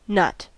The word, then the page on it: nut: American English